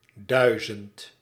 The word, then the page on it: thousand: /ˈθaʊz(ə)nd/